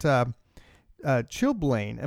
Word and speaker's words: chilblain: What about chilblain? Am I saying this correctly? – Yes. – I don't know this one at all. So let's find out about chilblain.